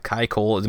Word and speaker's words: Kai Cole: But his ex-wife, Kai Cole, am I saying that right? – I believe so, yeah.